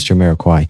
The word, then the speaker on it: Jamiroquai: Never quite sure how to pronounce the name of that artist. I'm pretty sure it's Jamiroquai. Either way, it's a brilliant song.